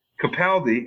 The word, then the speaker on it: Capaldi: Capaldi, and I found out that's how you pronounce his name, not Capaldi, because we do the short A thing here in the States, was wonderfully brilliant.